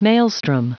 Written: maelstrom

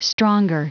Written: stronger

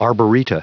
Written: arboreta

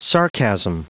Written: sarcasm

